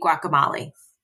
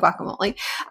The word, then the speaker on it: guacamole: So it just, you know, I know that you pronounce the E in guacamole.